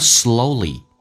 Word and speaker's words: slowly: You can learn slowly. 你可以学的比较慢一点点。 Slowly. O 这个发音。最后一个尾音是长元音 slowly, and sometimes painfully.